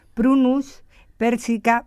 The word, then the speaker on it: Prunus persica: El estudio de la maduración de variedades del melocotón Prunus persica Vax. ¿Lo he dicho bien? – Sí. – Vale. – Muy bien.